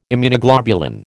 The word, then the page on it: Immunoglobulin: American English